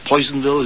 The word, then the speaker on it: Personville: Personville, Poisonville, as it's pronounced,